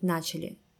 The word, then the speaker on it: начали: Правильно будет начали, с ударением на первом слоге – Ох уж эти русские ударения.